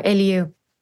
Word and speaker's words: Eliou: Like I just was telling you guys earlier, started with Dura, the brand Eliou. By the way, am I pronouncing it correctly? – Yes, you are, Eliou.